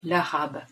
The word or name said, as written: l'arabe